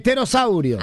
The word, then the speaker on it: pterosaurio: El pterosaurio se escribe, empieza con la P. Claro, con la P. – Pero no se pronuncia.